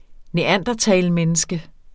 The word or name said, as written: neandertalmenneske